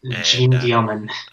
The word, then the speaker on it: Jeanne Dielman: And it is Jean Dielman. Am I saying that right, we think? – Sure. Jean Dielman.